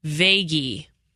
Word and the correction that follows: Verhaeghe: Did I do it right? – No, you didn't. – Verhaeghe. Carter Verhaeghe. We'll